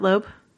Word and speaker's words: Loeb: Curious if any of you know how much uh, Jeph Loeb, is that how you pronounce it, Loeb? Yes.